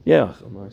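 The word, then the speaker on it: יאכל: Ye'ochol, I said it right.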